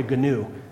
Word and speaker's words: gnu: Gnosis, G-N-O-S-I-S, kind of like a gnu, except gnu is pronounced new, right? The G is silent.